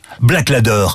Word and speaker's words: Blaklader: Black Ladder, ça s'écrit BLAKLADER.